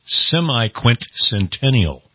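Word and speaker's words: semiquincentennial: It took me a whole day to learn how to say the official word for that, and it's semi-quintcentennial. – Okay. Semi-quintcentennial.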